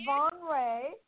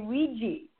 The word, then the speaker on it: Van Rij: Ah, you said Von Ray. It's pronounced like a ray of sunshine. But when you see it written R-I-J, most people here in North America say Ouija.